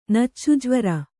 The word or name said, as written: ನಚ್ಚುಜ್ವರ